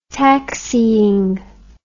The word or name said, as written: taxying